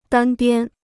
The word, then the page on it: 单边: dān biān